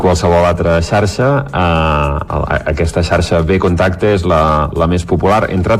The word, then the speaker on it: Vkontakte: Què és exactament, a veure si ho sabré pronunciar bé, la xarxa social Vkontakte? – Vkontakte.